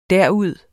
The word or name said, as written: derud